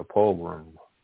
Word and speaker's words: pogrom: He called it a program. A program. Is that how you say it? No. He called it. – It is a strange word. It's spelled P-O-G-R-O-M. – Like a pogrom.